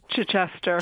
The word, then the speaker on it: Chichester: This is, am I pronouncing it right, Chichester in England? – Chichester.